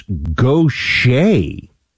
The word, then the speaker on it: Gautier: How to pronounce Gautier, it's Gautier. I'm from Mississippi, Paul says. I would not have gotten this. So that's like a Cajun, like Bobby Boucher or Bobby Gautier. That's a weird pronunciation.